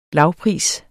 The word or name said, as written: lavpris